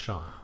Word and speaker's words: Shaun: It's spelled Shaun, but I think it's pronounced Sean.